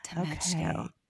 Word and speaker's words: Tomechko: Tomechko.